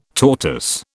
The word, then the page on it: tortoise: British English